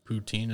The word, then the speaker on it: poutine: Poutine, sorry, I said it wrong, poutine.